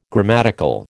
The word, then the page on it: grammatical: American English